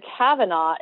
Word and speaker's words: Cavanaugh: It's Anise, A-N-E-S-E, and then Kavanaugh is C-A-V-A-N-A-U-G-H.